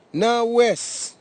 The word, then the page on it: nawes: Nafe; Tanna